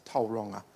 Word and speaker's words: Tauranga: Like Tauranga is not Tauranga, it's Tauranga, apparently.